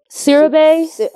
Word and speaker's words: Siriboe: Kofi, how do you say his last name? – Sirabe. – Okay. – Sirabe. I hope I'm saying that right.